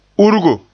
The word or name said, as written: urgo